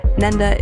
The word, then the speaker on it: Nenda: Nenda is spelled N-E-N-D-A.